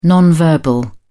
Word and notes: non-verbal: British English

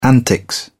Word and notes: antics: British English

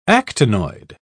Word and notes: actinoid: British English

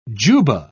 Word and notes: jubbah: British English

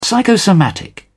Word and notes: psychosomatic: British English

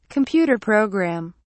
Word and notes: computer programme: British English

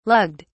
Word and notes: lugged: British English

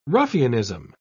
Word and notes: ruffianism: British English

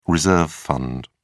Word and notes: reserve fund: British English

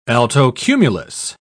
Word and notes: altocumulus: British English

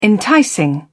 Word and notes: enticing: British English

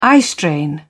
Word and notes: eyestrain: British English

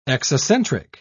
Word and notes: exocentric: British English

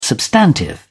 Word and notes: substantive: British English

